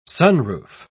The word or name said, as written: sunroof